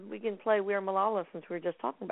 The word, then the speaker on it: Malala: So we have a couple songs in here, The Rose Is Gonna Ride, and we are, let's see if I'm saying it right, Malala or Malala? – Yep. – I'm not. – We are Malala, about Malala Yousafzai.